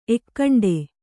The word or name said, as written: ಎಕ್ಕಂಡೆ